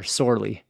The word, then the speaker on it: Sorley: We're going to start with Clan Sorla or Sorley. – Yeah, so starting with them, That refers to Summerled or Sorley's the Gaelic pronunciation and spelling.